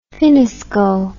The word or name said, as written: finnesko